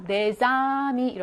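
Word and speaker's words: des amis: 원래는 데라고 발음을 하는 단어예요. 데스라고 하지 않아요. 그런데 뒤에 나오는 아미의 영향을 받아서 내자미 이렇게 발음이 새로 나타나게 되는 거죠.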